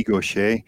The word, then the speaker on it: Gaucher: Uh, I'm going to pronounce it as I would say it in French, uh, Bishop Guy Gaucher, but yeah.